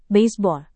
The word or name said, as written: baseball